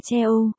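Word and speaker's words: Che Ô (加油): Du, trong, cố lên, như có giọt dầu phía dưới thật, cố lên tiếng Trung là Gia Yêu, đọc là Che Ô, nghĩa riêng từng từ là châm dầu.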